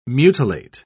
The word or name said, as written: mutilate